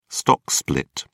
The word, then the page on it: stock split: British English